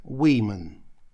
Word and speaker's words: women: А вот «женщины» – «women». Как видите, отличается у нас только последний, предпоследней буквой, когда женщина – это буква «A», а тут буква «I», но произношение иное – «women».